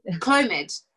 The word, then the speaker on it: Clomid: The Clomid tablet, if I'm saying that right. – Clomid? – That's it.